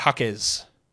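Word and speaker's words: Jaquez: And then what about Jovic and what about Hakez? Two blokes with the soft J's.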